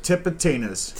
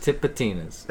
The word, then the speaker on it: Tipitina's: I think it's T-I-P-I or T-I-P-P-I-T-I-N-A-S. Tipitina's. Say it. Tipitina's. – Tipitina's. – That's it.